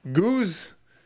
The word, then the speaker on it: Ghuze: G-H-U-Z-E. – Goose.